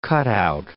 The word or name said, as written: cutout